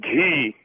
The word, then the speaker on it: ghee: First of all, I'd like to say that the pronunciation of that word is not G, it's ghee. – Ah! – And it definitely does come down. – I told you, Roger. I told you it was not G. It's ghee. You get so bullheaded, you don't want to listen to the voice of reason. – I'm not sure what the listener's talking about. Ghee? – It's ghee. G-H-E-E is pronounced ghee.